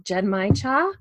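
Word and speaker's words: Genmaicha: Yeah, so today I have I hope I'm pronouncing this somewhat right because it's not English. It's called Genmai Cha. So cha being tea.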